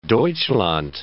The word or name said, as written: Deutschland